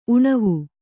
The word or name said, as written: ಉಣವು